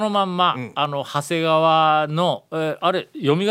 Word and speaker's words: 長谷: まあ、そうですね、ということは長い谷と書いて、うん、長谷言うて、